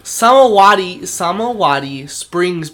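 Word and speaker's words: Samawati Springs: I don't know how to pronounce this. Samawadi Springs Pool. – Correct or not? That's what I would have said. – Perfect.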